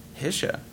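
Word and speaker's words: Hische: Well, Hisch. I mean, technically, I pronounce it wrong too. The Germans would probably sneer at me, but how would the Germans say it? – Hisha.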